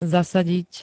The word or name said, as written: zasadiť